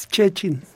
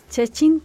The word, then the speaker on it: Szczecin: Es Szczecin. – Szczecin, Polonia.